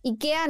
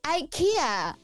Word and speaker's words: Ikea: Entonces, ahí dice IKEA, no es IKEA. No, no es IKEA. – es Ikea. Es Ikea. sí.